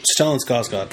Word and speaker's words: Stellan Skarsgård: Stellan Skarsgård.